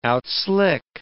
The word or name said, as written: outslick